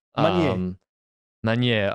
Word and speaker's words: Magnier: I like Magnier. I can't say his name properly. – Magnier.